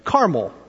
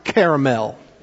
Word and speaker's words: Carmel: Carmel. Or if you grew up in the South like I did, it's pronounced Caramel, right?